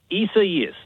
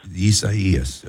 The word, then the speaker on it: Isaias: Yeah, and how are you guys pronouncing the rainstorm, the tropical rain? – Isaias, that's the name of the hurricane right now in the Gulf of Mexico. – Isaias, okay. All right, I think I got it right.